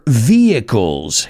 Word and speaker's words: vehicles: If you haven't figured it out yet, we're going to be talking about cars today or vehicles. Esa siempre es difícil a la hora de pronunciar. Vehicles. Yeah, la H es muda.